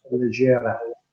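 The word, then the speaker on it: leggera: So I found it in the pronunciation, by the way, is Legera, Italian, Legera.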